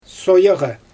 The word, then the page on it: soojaɣɯ: Xyzyl